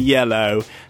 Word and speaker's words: Hyello: Now, that's spelled spelled H-Y-E-L-L-O. So it's like a mixture between hello and yellow.